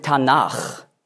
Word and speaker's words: Tanach: Aber man sagt dann Ch, weil dieser Konsonant weich ausgesprochen wird, wenn ein Vokal voraufgeht. Also Tanach ist die Abkürzung für Torah, Neviim, Ketuvim.